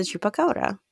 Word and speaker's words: chupacabra: So the surprise, the cryptid is a chupacabra.